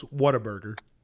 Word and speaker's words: Whataburger: Well, you're close. Whataburger. – Whataburger? – Yeah, that's what it's called. It's spelled What a Burger.